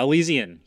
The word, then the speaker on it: Elysian: imperial pumpkin ale from Elysian did I get it right that time? – uh, Elysian, yes.